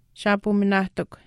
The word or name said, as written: shaapuminaahtikw